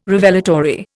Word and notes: Revelatory: Indian

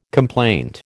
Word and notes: complaint: American English

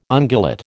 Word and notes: ungulate: American English

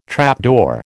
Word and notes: trapdoor: American English

